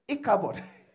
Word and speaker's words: Ichabod: Sister Mary, how do you pronounce it? You see, I'm right. Ichabod.